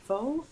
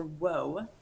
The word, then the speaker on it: Whau: It's spelled W-H-A-U, and it's pronounced Foe or Woe.